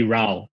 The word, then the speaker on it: Rao: The Mandarin phonetics is actually Rao, R-A-O. So even more confusion, right? Like, the Chinese pronunciation is Rao, but the Hakka pronunciation is Niao, right?